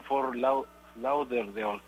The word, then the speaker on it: Fort Lauderdale: en un estadio nuevo que está en Fort Lauderdale, creo que se llama.